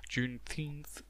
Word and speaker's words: Juneteenth: Juneteenth, zo moet ik uitspreken.